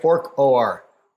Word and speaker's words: Orch OR: Orch OR, for orchestrated objective reduction.